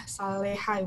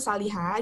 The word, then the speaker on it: Saleha: Saliha itu uh, double E ya, Saleha mungkin ya, ya dibacanya. Tapi dibaca itu Saliha, itu diambil dari nama aku ya sebenarnya nama belakang.